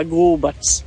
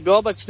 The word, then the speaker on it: Gobac: By the way, zove ga Gubac. Ono, čisto nije mogla izgovoriti Gobac, nego od kad ga obožava, onda je on Gubac.